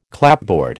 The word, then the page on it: clapboard: American English